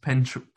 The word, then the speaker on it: Prentice: I believe it's pronounced Prentice. Prntise.